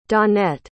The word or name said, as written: dawnette